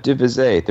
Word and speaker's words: De Visé: Well, that's right, De Vise. There's a town in Belgium called Vise, and long ago somebody in my family was De Vise.